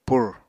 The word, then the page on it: pur: apatani